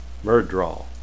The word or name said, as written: fade